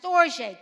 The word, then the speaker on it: storge: It's pronounced storge, S-T-O-R-J-A-Y, spelled on the board, S-T-O-R-G-E.